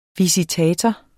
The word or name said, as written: visitator